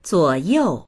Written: zuo3you4

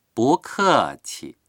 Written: bu2ke4qi

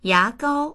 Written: ya2gao1

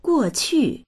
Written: guo4qu4

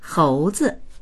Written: hou2zi

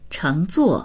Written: cheng2zuo4

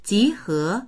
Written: ji2he2

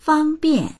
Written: fang1bian4

